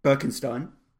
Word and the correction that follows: Benkenstein: Benkenstein, I believe. Good. It's pronounced.